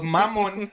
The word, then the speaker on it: Mammon: ¿No es Mammon? – Pues Mammon. Suena casi igual.